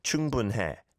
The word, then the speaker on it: 충분해: 충분해, 충분해. Is how you would say that naturally. The hiat sound is not over-pronounced.